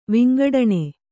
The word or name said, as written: ವಿಂಗಡಣೆ